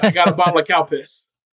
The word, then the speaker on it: Calpis: C-A-L-P-I-S. – Cow piss. – Cow piss. – Okay. – You literally cannot pronounce it without it sounding like cow piss.